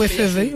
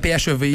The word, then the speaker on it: PHEV: Est-ce que ça se prononce comme ça? Je l'ignore. – Ou FEV. – Ça serait PHEV. – PHEV. – Mais on peut dire Fev, c'est sympathique.